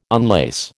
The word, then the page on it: unlace: American English